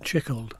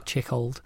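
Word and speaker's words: Tschichold: So one of your early influences, as well as Abram Gaimes, was actually Jan Tichold, is that how you pronounce it? – Chickold. – Chickold.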